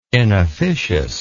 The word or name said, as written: inofficious